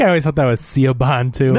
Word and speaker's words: Siobhan: It was like Siobhan. Like, there's a character in Superman comics called the Silver Banshee, and her name, her real name is Siobhan, but it's spelled like S-O-B-I-A-N. So I was like, Sobian.